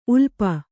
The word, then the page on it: ಉಲುಪಿ: ulupi